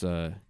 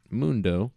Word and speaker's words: Moondough: The next guy was this band named Mundo, not spelled like Dr. Mundo from League, like I thought. It's Moondough, like cookie dough. Mundo.